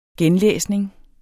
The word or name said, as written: genlæsning